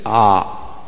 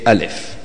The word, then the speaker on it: Alif: So we say it Alif. That's how you would read it. You won't say Ah, because that's not how we read.